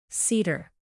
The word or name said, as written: cedar